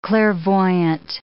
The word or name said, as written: clairvoyant